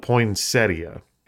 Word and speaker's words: poinsettia: It's spelled poinsettia. And, well, and you know, the internet tells me that's how it's pronounced. Well, Siri's not sure, but Google says it's poinsettia.